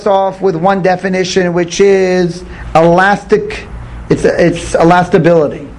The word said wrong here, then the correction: elasticity: Elasticity. – Thank you. I knew I didn't say it right. Elasticity.